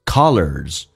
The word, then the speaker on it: colors: 第一个单词叫 color，打开口腔，在美式发音比较夸张一点到极限， color，轻轻的卷一下舌头， lers， colors，元音后面加 s 发 z 这个发音。